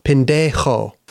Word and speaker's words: pendejo: No, you got to say it right, man. Pendejo. – My tongue is not going to... Pendejo.